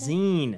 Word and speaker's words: zine: Is that how you said zine. – It? – Zine. Magazine. Zine.